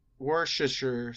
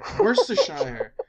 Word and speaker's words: Worcestershire: Worcestershire. I don't think the R is pronounced. It's like Worcestershire. – It's like, well, I don't know, Norwich. Norwich.